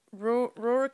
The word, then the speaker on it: Ruhr: The Serial Killer Day is also known as Rural Cannibal, Rural Hunter, or his, you know, actual name. – Rural? – Rur. It's a different. Like, yeah, it's Rur. Yeah. – Rur. – How do you spell it? – R-U-H-R.